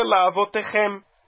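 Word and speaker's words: La'avotechem (לַאֲבֹתֵיכֶם): Então, o tá mesmo está embaixo do raf, e essa palavra, então, é uma oxítona que se lê La'avotechem. La'avotechem, chem, chem é a sílaba tônica.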